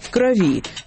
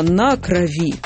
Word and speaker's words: крови: То есть в предложном падеже с предлогами «в» и «на» ударение на слове «кровь» перемещается с корня на окончание «о крови», но «в крови» и «на крови». Я была бы рада сказать, что кровавая тема на этом исчерпана, но нет, есть еще слово «кровоточить».